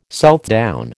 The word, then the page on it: Southdown: American English